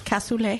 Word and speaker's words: cassoulet: What's the correct way? – You can't pronounce it casually. – What's the right way? – It is cassoulet.